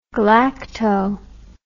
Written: galacto

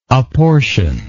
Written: apportioner

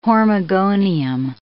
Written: hormogonium

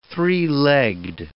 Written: three-legged

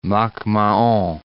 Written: Mac-Mahon

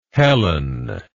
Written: helen

